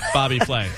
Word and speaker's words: Bobby Flay: In beat Bobby Filet. – Flay. – I can't wait to go over there. – And it's actually pronounced beat Bobby Flay.